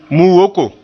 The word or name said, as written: mu-woko